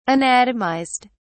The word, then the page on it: anatomized: British English